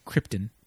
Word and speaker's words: Krypton: So he's wearing it on Krypton, or Krypton. – Krypton. – As he pronounces it. That's kind of been woven into, like, the mythology, right? – Yeah, and then that is now the current, like, reasoning for it. Yeah. It's not an S for Superman. It's the shield of the House of El. – Yeah. – Which is, when you said Krypton, You said that's the way he pronounces it.